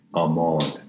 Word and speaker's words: Amon: When you worship your divine parents before that altar, Amon rhymes with gone.